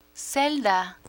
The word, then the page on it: celda: spanish